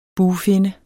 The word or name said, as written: bugfinne